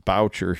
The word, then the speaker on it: Boucher: Yeah, so we pronounce it Boucher here in the Midwest.